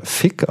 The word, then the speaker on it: VIC: VIC spricht man ja Fick aus, das geht gar nicht.